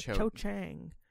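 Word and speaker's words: Cho Chang: No, every time I say her name, it's like Cha-Chang. Like Cho-Chang. Cho-Chang.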